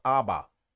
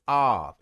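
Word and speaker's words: Av (אב): Aleph Beyet spells father, and father in the Hebrew is not pronounced Abba. It's Av. It's not 2 bets on it. Like Abba, no, that's not it.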